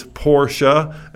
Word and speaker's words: Porsche: I've heard people who should know better call it Porsche, but it's Porsche. And the Germans just, once again, go absolutely crazy when you mispronounce it. In Germany, it's P-O-R-S-C-H-E, and the E is not silent. You pronounce the E.